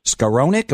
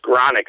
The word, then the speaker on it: Skaronic: And then the transfer, Bennett Skaronic. Am I saying that right? – Skaronic, I believe. – Skaronic, okay. I'm giving a long sound to that O.